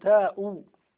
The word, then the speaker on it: تاء: تو ولا تاء التان ولا تاء الفاعل؟ تاء. أنا قلت تو، نقول تاء.